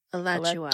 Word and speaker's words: Alachua: A-L-L? – No, A-L-A-C-H-U-A. – Mm-hmm. Alachua, also locally Alachua, Florida. – Alachua.